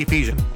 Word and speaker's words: Ephesian: Ephesian. Am I saying that correctly? – Ephesian. – Ephesian believers for 3 years and knew of their faith.